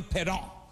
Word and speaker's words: Parent: a guy named Bernie Perron. Perron, but spell parent. Perron, but spell parent. But French do that. They make E-N-T silent. All their words, the words sound cool. Perron.